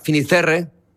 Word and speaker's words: Fisterra: Entonces llegó a Finisterre, ¿se dice así? – Fisterra.